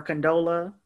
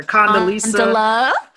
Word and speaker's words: Condola: Black Twitter, y'all are disrespectful for Condola. Y'all kept mispronouncing her name, calling her Condoleezza.